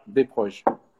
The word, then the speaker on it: Desproges: Se pronuncia Desproges.